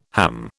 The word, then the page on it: ham: British English